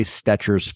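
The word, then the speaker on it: Stecher: How do you say his last name? Stetcher. – Troy Stetcher.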